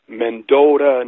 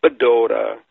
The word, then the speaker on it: Mendota: It was Medewakanton, Mendota, not Badota.